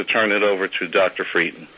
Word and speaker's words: Frieden: Here to discuss the Vital Sign report is CDC Director Thomas R. Frieden, that's spelled F-R-I-E-D-E-N.